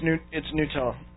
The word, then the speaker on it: Gnutella: It's called, I think it's probably pronounced Nutella. Gnutella. – No, it's Nutella. – Nutella? Okay. – Yeah, the G silent.